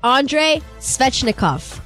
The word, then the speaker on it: Svechnikov: Andre Svechnikov. Did I say that right? – Yes, you did. Right! You got it right.